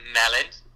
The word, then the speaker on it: melyn: It is Melyn. – Merlin. – It's melon.